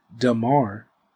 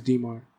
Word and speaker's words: Dmar: I'm going to start calling me Dmar because I don't know if people, first of all, people be saying Dmar. – Okay. – Which is perfect. That's fine. You're just mispronouncing it. Mm-hmm. It's just Dmar.